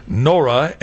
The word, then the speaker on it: Nohra: Guy Nora, that's G-U-Y, pronounced Guy, Nora, N-O-H-R-A.